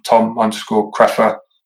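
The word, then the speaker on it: Tom Kreffer: I'm on all the socials, Instagram, Tom_Craffer. Just type in Tom Craffer anyway. My surname is quite unusual. It's spelled K-R-E-F-F-E-R.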